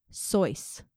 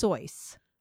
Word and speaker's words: Seuss: The fact that I used today, do you know how to pronounce Dr. Seuss's name? Because it's not fucking Dr. Seuss. – What? – His last name is pronounced Soyce. – No, it's not. – It's pronounced Soyce. – What the hell? He's just been letting everyone say Seuss? – Soysicle the Moysicle. It's not Seuss.